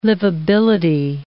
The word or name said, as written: livability